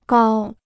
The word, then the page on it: 高: gāo